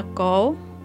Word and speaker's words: cổ: Chữ cổ, nhà buôn vốn đọc là giả, dối trá. Nhưng ở đây đọc là cổ.